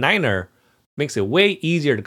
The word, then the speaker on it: niner: And finally, why in the NATO phonetic alphabet do you pronounce the number 9 as niner rather than saying just 9? – So that's to avoid confusion with saying 5.